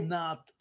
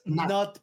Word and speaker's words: Pnath: Not. De hecho, es Not, porque está basado en un tema de Lovecraft.